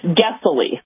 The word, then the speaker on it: Gessily: No, it's Gessily. Like oh, Guess. G. G.